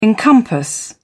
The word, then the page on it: encompass: British English